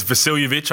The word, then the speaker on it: Vasiljevic: I've been saying his name wrong for a little bit. It's Vasiljevic, I found out.